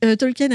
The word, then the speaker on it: Tolkien: Tolkien a initialement conçu Le Seigneur des Anneaux comme une suite de son roman Le Hobbit.